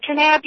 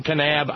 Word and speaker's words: Kanab: It's Kanab, Utah. – Do you pronounce the K in Kanab? I was kind of wondering. – Yeah, you do.